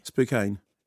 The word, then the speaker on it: Spokane: In Washington, S-P-O-K-A-N-E. – Spokane. – It's pronounced Spokane. As though there isn't an E.